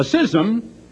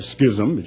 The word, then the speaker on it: schism: A schism, and that is the correct pronunciation, some people like to call it a schism, just look it up in the dictionary,